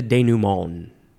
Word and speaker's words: denouement: The next word, it is denouement, or denouement. I think you can emphasize that one instead. Denouement, that's how I've heard it pronounced. It is spelled D-E-N-O-U-E-M-E-N-T, and you could put an accent on the very first E, which goes whoop, if you want the accent or not.